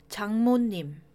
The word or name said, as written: 장모님